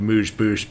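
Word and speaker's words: amuse-bouche: I feel like I'm about to be corrected on my pronunciation of moosh boosh by a Londoner, and I'm going to be shamed by it.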